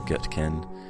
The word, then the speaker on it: Gootkin: Notice the Gutkin, the way I'm pronouncing it right now. I know it's spelled G-O-O-T-K-I-N, and consequently I used to get a lot of "Hey, Gutkin," like people would really overpronounce the O-O, and it's not that way,